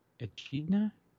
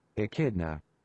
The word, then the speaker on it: Echidna: The E-C-H-I-D-N-A. How do you say that? – And she, I know, I remember we looked this up last time. – How do you pronounce? – Are we going to get to here? There we go. Yep. – Echidna? – Echidna. – Echidna. – Echidna. Oh, yeah.